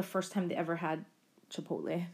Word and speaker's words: Chipotle: I think some of you saw in the news, JK mispronounced Chipotle in the January 23rd Bangtan Bon as Chikotle.